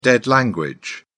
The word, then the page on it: dead language: British English